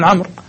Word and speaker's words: عمرو: الشمسية مثلا، مثل الواو الجماعة، مثل الواو اللي في عمرو، ما حد ينطقها عمرو صح؟ ولكن يقولون عمرو، والواو يقولون للتفرقة بينه وبين عمر مثلا.